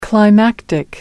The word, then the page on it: climactic: British English